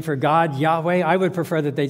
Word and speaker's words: Yahweh: It's pronounced Yahweh, Yahweh. In the King James Bible, you find the word Jehovah, Jehovah.